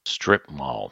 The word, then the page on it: strip mall: noun